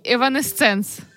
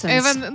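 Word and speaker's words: Evanescence: В неї висіли плакати таких гуртів, як Нірвана і Еванесценс. – Еванесенс.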